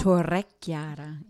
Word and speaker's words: Torrechiara: And then we're going to visit the Castle Torquiara. Did I say that right? – Torrechiara. Yep.